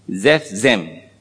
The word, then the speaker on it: Zephzem: M-I-N-A-H. J-O-M-I-N-A-H. So you got Hojao, Jomina, and the last one, the third name is Zephzem. Z-E-P-H. Z-E-P-H, the P-H you can pronounce as F, Zephzem. Zephzem. Z-E-P-H, continuation, Z-E-M.